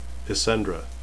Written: isendre

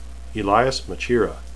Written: elyas

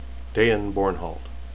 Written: dain

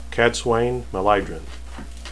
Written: cadsuane

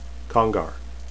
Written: congar

